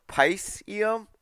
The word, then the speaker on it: Piscium: Their pronunciation leans closer to Pisceum or Pisceum.